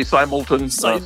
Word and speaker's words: Simelton: Am I saying your name right, by the way? – I say Simelton.